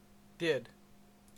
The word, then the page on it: did: CA; [dɪd]